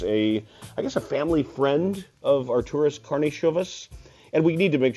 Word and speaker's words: Karnišovas: He was always Karnišovas, and I guess as now we know, the more traditional, the more correct Lithuanian pronunciation would be Karnišovas.